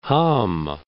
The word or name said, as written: Hamm